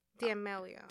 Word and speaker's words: D'Amelio: Charlie D'Amelio. – D'Amelio.